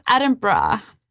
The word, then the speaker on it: Edinburgh: So Hayley was like, I would love to shout out, and that happened in Edinburgh. And I was just like, no, it's Edinburgh.